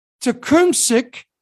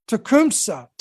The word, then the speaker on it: Tecumseh: The Shawnee leader, Tecumseh, I believe that's how you pronounce that, Tecumseh,